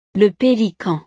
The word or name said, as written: le pelican